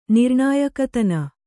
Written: ನಿರ್ಣಾಯಕತನ

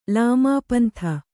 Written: ಲಾಮಾಪಂಥ

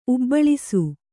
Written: ಉಬ್ಬಳಿಸು

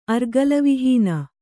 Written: ಅರ್ಗಲವಿಹೀನ

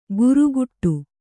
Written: ಗುರುಗುಟ್ಟು

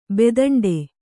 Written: ಬೆದಂಡೆ

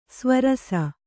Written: ಸ್ವರಸ

